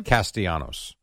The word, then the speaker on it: Castellanos: Castellanos.